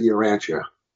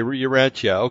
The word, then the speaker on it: Urantia: So they pronounce it Urantia. – Okay, Urantia.